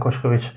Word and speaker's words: Koskovics: And my name is Zoltan Kuškovic.